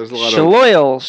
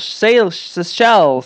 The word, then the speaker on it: Seychelles: like Shaloil, Seychelles.